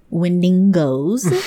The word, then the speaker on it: Wendigos: And not only that, coming up on Halloween, episode 55, I'm gonna talk about Bendigos. Is that what you call those? – I think it's pronounced Wendigos. Like, you gotta go up at the end or else you don't have the right accent.